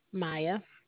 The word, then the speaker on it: Mya: Maya. – M-I-A? – Just M-Y-A, ma'am. – M-Y-A.